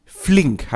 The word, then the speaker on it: Flink: Und es geht um Flink. Habe ich das richtig ausgesprochen? Carlo? – Du hast es perfekt richtig ausgesprochen.